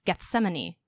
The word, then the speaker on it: Gethsemane: finale, I guess, Gethsemane. Did I say that right? – Yeah.